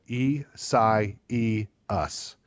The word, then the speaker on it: Isaias: I wrote down a phonetic spelling of it. E, Psi, E, Us. Broke it down into four syllables.